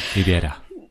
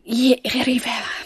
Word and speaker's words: Rivera: Rivera. – Rivera, genau.